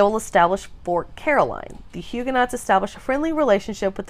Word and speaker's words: Huguenots: For instance, the Huguenots of France. So Huguenots is spelled H-U-G-U-E-N-O-T-S, but these are Calvin's French followers.